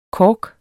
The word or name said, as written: KORKENS